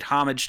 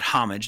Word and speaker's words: homage: But one of the, there's, in my opinion, there's two extremely notable scenes that this film is known for and imitated and been paid homage to, not homage. The H is silent.